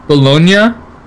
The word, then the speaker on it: Bologna: Also, uh, Bologna in Italy. That's that's how you say it.